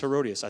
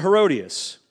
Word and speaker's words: Herodias: So, and if I get slipped up, I'm sorry, but I guarantee you that's Herodias. I thought it was something different.